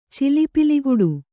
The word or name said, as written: ಚಿಲಿಪಿಲಿಗುಟ್ಟು